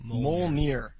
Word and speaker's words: Mjolnir: And one more thing from the mailbag I have is that Fulkin says that Mjolnir is pronounced Mjolnir.